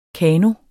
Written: kano

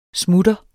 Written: SMUTTERNES

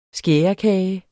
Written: skærekage

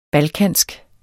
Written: balkansk

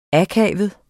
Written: akavet

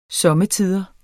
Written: somme

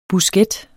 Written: busket

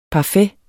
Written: parfait

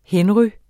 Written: henry

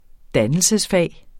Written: dannelsesfag

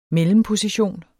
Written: mellemposition

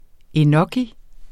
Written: enoki

